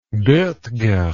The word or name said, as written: bottger